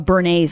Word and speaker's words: béarnaise: Yeah, ketchup instead of béarnaise sauce. Is that what it's called? – Yes, béarnaise sauce. – Béarnaise. Oh, thank you, Jared. Thank you for that pronunciation.